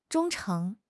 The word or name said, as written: 忠诚